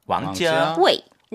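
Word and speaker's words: 왕자웨이 (王家衛): 정확한 발음으로는 왕 높아야 되잖아요. – 왕. – 이성 왕. 왕 그리고 집 가짜예요. 네. 지아 알죠? 알고 있죠. – 네네. – 왕자웨이 왕자웨이 웨이는 몇 성이에요? – 사성.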